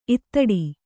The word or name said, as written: ಇತ್ತಟ್ಟು